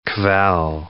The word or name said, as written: kvell